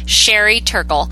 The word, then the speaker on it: Sherry Turkle: I misspoke and I said her name wrong earlier. Sherry Turkle.